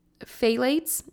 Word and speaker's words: phthalates: Next on the list is, and this is kind of a mouthful, but it's phthalates, I believe is how you say it. It's got a PH and a TH in there, but it's spelled PHTHALATES.